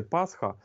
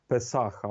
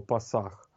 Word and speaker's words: Песах: І якщо дивитися у Святе Писання, то, ну, по-перше, треба сказати, от ми звикли завжди казати Пасха, а взагалі, по суті, дослідники вже змогли довести, що вимовлялося це слово саме як Песах або Пасах.